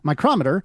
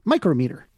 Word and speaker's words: micrometer: In spoken English, they are distinguished by pronunciation as the name of the measuring device is stressed on the second syllable, micrometer, whereas the unit name places the stress on the first syllable, micrometer.